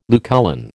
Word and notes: Lucullan: American English

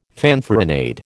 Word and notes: fanfaronade: American English

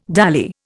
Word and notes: dally: Indian

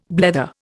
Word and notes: blether: Indian

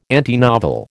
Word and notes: antinovel: American English

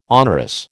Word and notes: onerous: American English

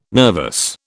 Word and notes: nervous: British English

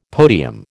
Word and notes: podium: American English

